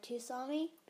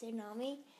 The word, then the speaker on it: Tsunami: The next book, I believe, is about Tsunami, which whenever I was little, I used to call her Tsunami because it's spelled Tsunami, but it's Tsunami.